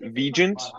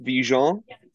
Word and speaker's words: Vigeant: I have been raised to pronounce it Vigeant. – Okay. – But I'm soft launching Vigeant. – Vigeant. – Vigeant.